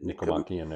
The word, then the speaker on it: Nicomachean: Nicomachean Ethics.